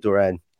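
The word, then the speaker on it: Durán: So John Duran.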